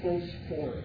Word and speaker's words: Phosphorus: Or actually, Phosphorus, if you want to get the Greek pronunciation.